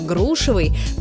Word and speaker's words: грушевый: Но вернемся к грушам и запомним, что ударение во всех формах прилагательного «грушевый» – падает на первый слог, как в слове «груша».